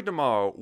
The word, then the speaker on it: DeMaio: Bassist Joey DeMaio, I think that's how you pronounce his last name, by the way. Joey DeMaio? DeMaio? D-E-M-A-I-O. Joey DeMaio. Sure.